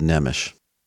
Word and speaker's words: Nemes: It's called Words of Life with Dr. Stephen Nemish, and his last name is spelled N-E-M-E-S. It looks like Nemes, but it's pronounced Nemish.